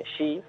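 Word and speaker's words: shi (四): En los hospitales y en hoteles y demás, normalmente el número 4 se suele evitar mucho, porque 4 en japonés, lo que sería shi, con diferente kanji, también se pronuncia shi, el nombre de muerte.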